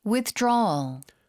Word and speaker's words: Withdrawal: Withdrawal 발음 좀 도와주세요. – Withdrawal. – Withdrawal. – Withdrawal. – 뒤쪽에 힘을 주고 좀 끌어주세요.